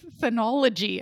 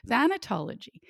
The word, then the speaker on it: thanatology: I want to say thanology. I'm like, no, I'm going to try that again. Thanatology.